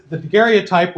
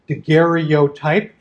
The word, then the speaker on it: daguerreotype: The daguerreotype, which I will pronounce either one of two ways. I sometimes pronounce it daguerreotype, as it's often spelled out and spelled out phonetically and pronounced that way in the 19th century, and then daguerreotype, which sort of squishes a syllable together, a couple of syllables together. So